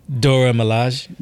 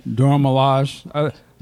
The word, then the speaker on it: Dora Milaje: Salute to the, how do you say their name? Dejan Malay or? – Dora Milaje. – Dora Milaje.